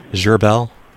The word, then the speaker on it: Gharbel: R-O-Y. What's your last name, Roy? – Gerbel. – Okay. Would you spell it, please? – Sure. G-H-A-R-B-E-L.